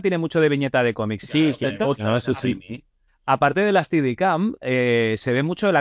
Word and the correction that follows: Steadicam: de, ¿Cómo se dice, tío? No, no, no, se dice Steadicam, pero que la Steadicam de ellos.